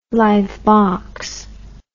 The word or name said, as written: live box